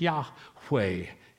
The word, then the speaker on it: Yahweh: Translates the Hebrew YHWH, Yahweh. Is how it is best pronounced, who has given rest to his people Israel, just as he has promised.